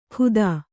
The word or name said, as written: ಖುದಾ